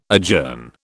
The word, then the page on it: adjourn: British English